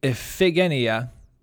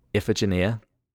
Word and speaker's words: Iphigenia: Iphigenia. – Iphigenia.